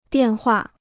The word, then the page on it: 电话: diàn huà